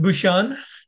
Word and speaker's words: Bucshon: It's pronounced Bouchon.